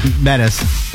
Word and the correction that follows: Yosemite: You didn't say it right, but we know what you meant. – Yeah. – Yes. Yosemite.